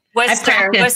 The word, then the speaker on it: Worcester: Worcester, England. Is that how you say it? – That's how you say it. – Worcester.